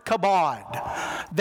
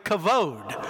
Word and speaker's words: kavod: The Hebrew word for glory, the primary word is the word kabod. That B is actually pronounced a V. It's actually kavod.